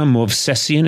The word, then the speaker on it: Movsesian: And of course, I'm aided, as always, by my assistant, Sona Movsesian. Is that the correct pronunciation? – It is. Yeah, it's good.